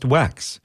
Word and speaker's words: WECX: Vivian Cottiga is co-general manager of WECX, pronounced WEX.